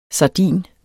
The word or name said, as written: sardin